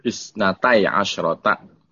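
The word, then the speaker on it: Isnatai asyrata (اثنتي عشرة): Wa humul anbiya'ul ladzina kanu fi qabaili bani Israilal isnati asyrati. – Isnatai asyrata. – Oh, isnatai. – Kalau apa namanya adat murakkab, dia itu mabni nal fathi, makanya bacanya isnatai asyrata.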